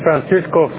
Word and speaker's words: Francisco: It's Francisco Flores 000001.